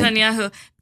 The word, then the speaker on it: Netanyahu: Netanyahu. I always say his name incorrectly. – Netanyahu. – Thank you.